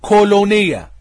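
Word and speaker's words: كولونيا (κολωνία): النص اليوناني بيستخدم كلمة الكلمة اليونانية تنطق حرفيا كولونيا. الكلمة اليونانية بتنطق حرفيا كولونيا، اللي هي بتترجم بالانجليزي الى كولوني.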